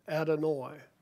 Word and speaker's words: Adonai: The word here is Strong's number 0136, pronounced Adonai.